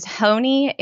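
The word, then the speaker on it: Honea: It does not look like that. So it really doesn't. – H-O-N-E-A. I married into it, so I didn't, you know, choose how to pronounce it. But I always just say Emily Honey rides a pony because then people remember that.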